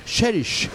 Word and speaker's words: Cherish: Cherish.